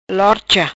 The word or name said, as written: Lorcha